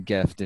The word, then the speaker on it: GIF: If you say it's anything else than gift, there's no hope for you.